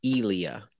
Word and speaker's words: Ilya: By the way, his name is pronounced Ilya. Ilya.